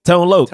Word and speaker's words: Tone Loc: Tone Loc.